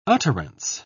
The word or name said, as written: utterance